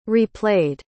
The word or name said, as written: replayed